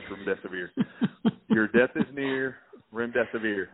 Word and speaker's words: remdesivir: Remdesivir, your death is near. That's how you pronounce it.